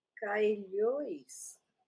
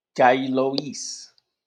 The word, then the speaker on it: Caillois: Español, si es español, es Caillois. – Caillois. – Caillois.